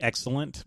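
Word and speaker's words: Xslent: It's probably excellent, but it's X-S-L-E-N-T, but it's probably pronounced excellent.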